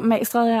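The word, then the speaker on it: Magstræde: Jeg ved ikke, hvordan man udtaler det. – Jo, Magestræde, ja.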